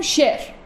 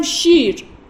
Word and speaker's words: شیر: مثلا من به جای شیر اگه بگم شعر، یعنی یه رو تلفظ نکنم، بهش میگفتم یای مجهول. یای مجهول یعنی اون یه تلفظ نمیشه. حالا برعکسش کامل تلفظ کنم بگم شیر، این میشه یای معروف برعکس مجهول، یعنی کامل تلفظ میشه.